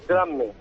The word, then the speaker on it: Drammen: porque es que también estáis también estáis en Drammen, Noruega, no sé si lo pronuncia bien, hay un belén en Noruega vuestro. – Correcto, fíjate, Drammen.